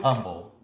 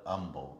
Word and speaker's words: Humble: Not Humble, but Humble. – Humble, yeah, it's weird. – The H is silent.